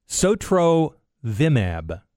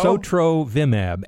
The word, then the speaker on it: Sotrovimab: It's Sotrovimab. – There you go. – Sotrovimab.